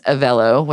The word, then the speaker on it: Avelo: And I think it's Avelo, which I finally found is how you say it, the A-V-E-L-O.